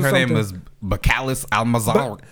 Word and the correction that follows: Belcalis: No wonder Cardi B's real name is Belcalis. – Oh, that's how you pronounce it.